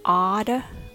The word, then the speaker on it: automatically: The word is pronounced as automatically. Let's break it down. First of all, when you say ah, make sure that you open your jaw. Ah. Next is d with flap t and the schwa sound. D. Next, mat has the stress, so it's the strongest syllable in this word. Say it nice and big. Mat. Next is again schwa. Notice how the T at the end of mat changes to flap T again.